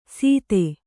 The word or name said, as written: ಸೀತೆ